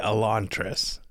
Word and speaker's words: Elantris: I say Elantris.